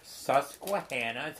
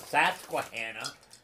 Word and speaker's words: Susquehanna: See, Susquehanna. It's not Susquehanna. It's Susquehanna.